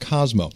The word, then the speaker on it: Cosimo: I know it's spelled Cosimo, but it's pronounced Cosimo.